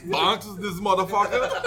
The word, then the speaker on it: Banks: And you can't say bank. You gotta say it like the Guyana. – Banks.